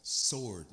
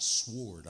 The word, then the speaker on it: sword: It's sword, not sword. I know the W is there, but it's silent.